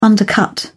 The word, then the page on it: undercut: British English